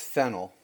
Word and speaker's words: Fehnel: It's Fennel.